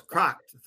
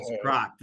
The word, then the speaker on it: Kragt: Well, it's pretty close. Kragt. Think of a crock pot with a T on the end of it. – Kragt. – Pretty okay. – Close. Kragt.